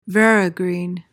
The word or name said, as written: verigreen